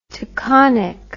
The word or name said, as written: taconic